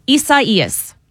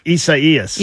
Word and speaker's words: Isaias: It's Isaias in Spanish. – Isaias. – Yeah, but the National Hurricane Center decided to go with the Spanish pronunciation.